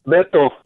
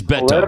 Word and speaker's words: Beto: So, but the name, is it pronounced Beto or Beto or Beto? – Beto. – It is Beto.